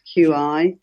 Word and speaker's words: qi: But if we just say to start off with that it is a difficult word, difficult thing to pronounce, and it doesn't pronounce as the way it looks, Q-I. So qi, a lot of people will know qi.